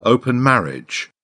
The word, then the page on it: open marriage: British English